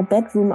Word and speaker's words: bdrmm: Also wir haben den Act immer Bedroom ausgesprochen, wird geschrieben BDRMM.